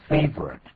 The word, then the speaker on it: favorite: 这边呢，我们看了有一个 favorite，这个字不要念成 favorite。 – 对，请注意啊，favorite 是没有错，查字典可能就是这个发音。可是，一般人讲话就是 favorite。 Favorite 两个音节就可以了。